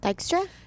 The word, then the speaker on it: Dykstra: D-Y-K-S-T-R-A? – Dykstra? – Dykstra.